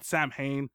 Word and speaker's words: Samhain: It's spelled Samhain, yeah.